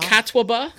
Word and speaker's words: Catawba: Is that how you say it? Catawba? – Catawba.